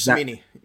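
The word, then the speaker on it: Cimini: Rich Karimi, I'm going to say that wrong. He covers the Jets, and he talked about – Rich Samini. – Yeah. Samini. Mm-hmm.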